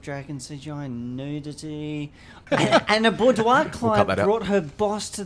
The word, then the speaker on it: boudoir: Boudoir. – Okay, thank you. – Boudoir.